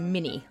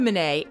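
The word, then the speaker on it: Minié: Most Americans pronounce this round as mini, like the mouse. But the guy who invented it was French, so Manet is probably more correct.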